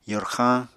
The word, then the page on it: yorxañ: apatani